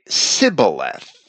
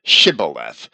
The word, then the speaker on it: Shibboleth: But there is an account of the tribe of Manasseh not being able to pronounce the word Shibboleth properly in Judges chapter 12, verse 6, where they would say Sibboleth rather than Shibboleth.